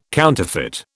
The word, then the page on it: counterfeit: British English